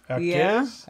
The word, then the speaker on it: Jaquez: Well, the J is an H. – Jaquez? – Yeah, I think it's Jaquez.